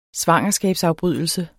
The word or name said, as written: svangerskabsafbrydelse